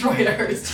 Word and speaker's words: Reuters: I think it's pronounced Reuters. – It is pronounced Reuters.